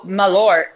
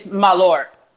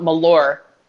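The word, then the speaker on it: Malört: You know, Malort is how you pronounce it in Swedish, and that translates to wormwood. – Right, isn't is that what I was Malort? Told. – Wasn't I pronouncing it like that? – It's Malort. It's like not Malort. It's not like its name is Laura and you call it Ma and took the A off.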